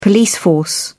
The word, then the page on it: police force: British English